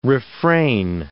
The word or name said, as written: refrain